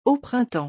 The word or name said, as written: au printemps